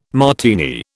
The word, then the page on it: Martini: British English